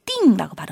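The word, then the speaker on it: 腚: 중국어로는 띵이라고 발음해요.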